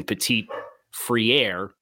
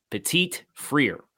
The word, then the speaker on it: Petit-Frere: So Nicholas Petit-Frere, and I have the phonetic pronunciation guide here next to me because it's always been Petit-Frere. It's Petit-Frere.